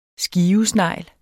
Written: skivesnegl

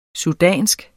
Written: sudansk

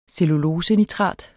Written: cellulosenitrat

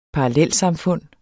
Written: parallelsamfund

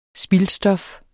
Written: spildstof

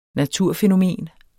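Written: naturfænomen

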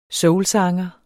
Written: soulsanger

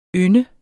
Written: YNDEN

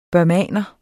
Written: burmeser

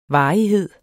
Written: varighed

